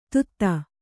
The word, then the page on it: ಧೂತ: Kannada